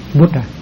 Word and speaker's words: Buddha: Đọc là Buddha thì nó không có đúng lắm, tại theo cái sự phát âm của tiếng Sanskrit thì cái âm A đọc rất nhẹ, Buddha, Buddha.